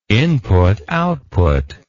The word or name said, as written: input-output